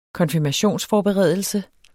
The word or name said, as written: konfirmationsforberedelse